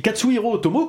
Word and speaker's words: Katsuhiro Otomo: Et Katsuhiro Otomo, comme tu l'as très bien prononcé tout à l'heure, Didouille, c'est quand même quelqu'un d'assez impressionnant.